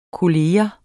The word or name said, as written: kollega